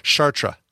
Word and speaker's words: Chartres: AB is the first one, I think, to actually give a real answer to how to pronounce that city in France, and it's Chartres.